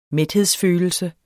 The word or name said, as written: mæthedsfølelse